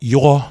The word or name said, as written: Yuhaw